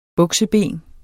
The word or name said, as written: bukseben